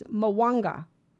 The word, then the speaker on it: Mwanga: And Magwanda or something, his name. I don't know how to say it. It's Mwanga, King Mwanga of Uganda.